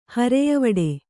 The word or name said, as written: ಹರೆಯವಡೆ